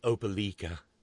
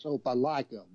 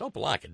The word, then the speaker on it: Opelika: Well, Mika pronounces it because, you know, Mika is such a coastal elite Euro trash snob. She pronounces it Opelika. – It's Opelika, woman. – It's Opelika, damn it.